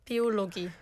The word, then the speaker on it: Biologie: Biologie.